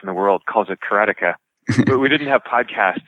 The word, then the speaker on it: karateka: I always said karateka, you know, but of course, the Japanese pronunciation wouldn't have a stress on any syllable, right? It would be something like karateka.